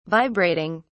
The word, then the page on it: vibrating: British English